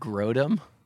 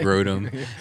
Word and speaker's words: Grodum: I had a rough name of Grodum, like a Grode bike. – A Grode bike. – Rhyming with scrotum, I thought that was good. – I like that, Grodum.